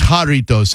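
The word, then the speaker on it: Jarritos: I don't know if you've noticed, but the Juaritos company is blowing up, I'm saying that right. – I think you are. I think it's Juaritos, actually. There's no U in there.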